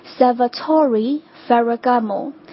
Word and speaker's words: Salvatore Ferragamo: 在中文应该叫费拉格摩，如果我没记错的话哈，它的这个英文的发音应该是 Salvatore Ferragamo。这两个单词它分别都是由四个音节构成，重音都是在第三音节 Salvatore Ferragamo， OK。